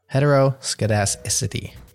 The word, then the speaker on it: heteroscedasticity: In round two, your word is heteroscedasticity. It's spelled H-E-T-E-R-O. S-C-E-D-A-S-T-I-C-I-T-Y.